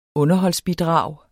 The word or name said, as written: underholdsbidrag